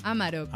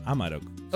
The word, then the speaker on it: Amarok: ¿Amarok o Amarok? ¿Cómo se dice? – Amarok. – Amarok.